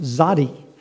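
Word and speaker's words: Zadi (צדי): In your Bibles, you will see probably that small character, that small letter, and it's pronounced Zadi.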